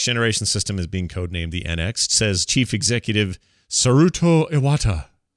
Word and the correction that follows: Satoru Iwata: Satoru, how do you say it? Satoru Iwata.